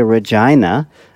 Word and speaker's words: Regina: Yeah, that's how it's pronounced here in Canada. We say Regina.